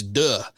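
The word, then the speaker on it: Deux: Yes, it's how you pronounce it. It's D-E-U-X. It's duh. Anyway, it's a French word.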